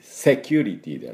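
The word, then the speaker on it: security: セキュリティではなくてね。どっちでしょう、この C。セをですね、強く、スッ、シって言うと、ちょっと I っぽく、いう発音になりますけどね。はい、ですから、セキュリティという発音にしてください。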